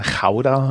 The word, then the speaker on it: Gouda: Mhm weil die Stadt ja nicht Gouda, sondern Chauda heißt, weil G ausgesprochen wird.